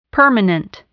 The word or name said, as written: permanent